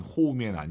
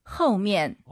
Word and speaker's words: 后面: 后面 아니에요. 오유는 어우니까 허우 생각하시면서 한번 더. – 后面.